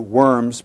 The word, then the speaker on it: Worms: April 17, 1521, this famous meeting at Worms, the W, Worms, pronounced with a V in German, this famous meeting where there Luther is tried as a heretic.